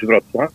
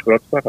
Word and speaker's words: Wrocław: Ja, also äh, wir sagen auf Polnisch Wrocław. Die Stadt heißt auf Polnisch Wrocław, auf Deutsch heißt, heißt sie Breslau, auf Tschechisch heißt sie Brat Bratislava.